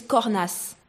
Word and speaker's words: Cornesse: It's Cornasse. – Cornasse. It is, because with French, most of the time you don't say the S on the end.